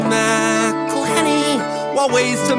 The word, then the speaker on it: McElhenney: McElhenney. – It's McElhenney. – McElhenney. – McElhenney. – McElhenney. – If there was doubt, now there won't be any. It's McElhenney.